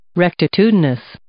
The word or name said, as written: rectitudinous